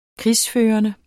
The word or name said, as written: krigsførende